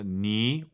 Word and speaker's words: 你: 삼성 삼성이니까 앞에가 이성이죠. 니 올라가야죠.